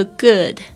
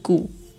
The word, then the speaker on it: good: 大家特别注意一下这个这个 good，有很多同学老师说，为什么你会念成古，不是古的吗？为什么会是 good？OK，特别注意一下那个 o o 的那个声音，其实是没有完全嘟嘴巴那个呃呃呃的声音。